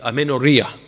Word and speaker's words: amenorrhea: There is a percentage of women who suffer from this condition called, it's called uh, amenor- uh, uh the pronunciation, amenorrhea.